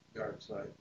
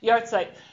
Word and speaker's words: Yahrzeit: Am I pronouncing that right? – Yahrzeit. – Yahrzeit. Thank you. Yahrzeit.